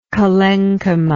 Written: collenchymatic

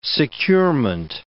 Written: securement

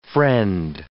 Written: friend

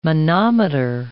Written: monometer

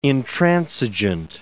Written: intransigeant

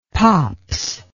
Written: pops

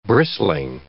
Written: bristling

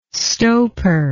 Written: stoper

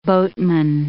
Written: boatman

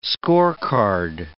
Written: scorecard

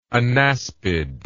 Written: anaspid

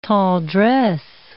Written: tendresse

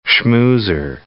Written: schmoozer

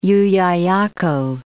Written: Llullaillaco

